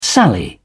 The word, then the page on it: sally: British English